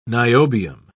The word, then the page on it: niobium: British English